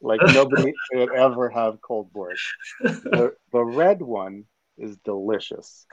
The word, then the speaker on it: borscht: It's pronounced borscht.